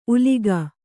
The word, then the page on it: ಉಲಿಗ: Kannada